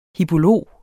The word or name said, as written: hippolog